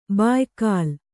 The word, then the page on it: ಬಾಯ್ಕಾಲ್: bāykāl